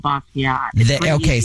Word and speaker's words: Basquiat: Basquiat? What, you put it in a basket? Is that what you said? – You work at this museum and you don't even know how to pronounce it? Jean-Michel Basquiat.